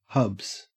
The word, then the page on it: hubs: Australian English